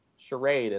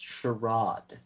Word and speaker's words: charade: That was the whole point of the whole charade, and no one bought it. – It's pronounced charade. – Charade? Excuse me.